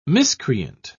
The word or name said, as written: miscreant